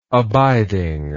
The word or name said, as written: abidingness